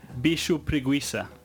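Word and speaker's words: bicho-preguiça: How do you know how to say this properly? – Bicho preguiça. – There we go. And which just means lazy beast, lazy animal. That's the Brazilian Portuguese word for sloth.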